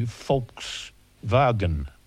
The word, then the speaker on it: Volkswagen: It could be Volkswagen. – Mm, yeah, if you pronounce the W as the Germans do, as a V.